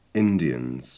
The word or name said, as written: indians